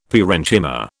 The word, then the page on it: parenchyma: British English